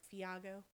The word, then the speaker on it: Thiago: Yeah, I pronounce it like Iago, Fiago.